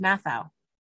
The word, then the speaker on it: Matthau: pronunciation is Matthau.